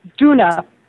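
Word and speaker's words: Doona: Talk to us a little bit about this book, Crisis on, pronounce the name for me. – Duna. – Duna. There's no way there, but I'll go with that.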